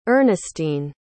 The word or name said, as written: earnestine